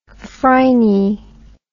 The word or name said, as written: phryne